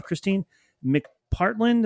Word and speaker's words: Kristine McPartlin: We have Kristine McPartlin.